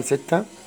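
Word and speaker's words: sept: رقم ستة، سيس سيس. سبعة، ست البي لا تنطق، ست ست سي ست.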